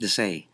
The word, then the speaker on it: Desai: Desai.